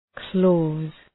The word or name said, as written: clause